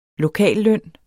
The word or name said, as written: lokalløn